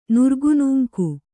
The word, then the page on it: ನುರ್ಗುನೂಂಕು: Kannada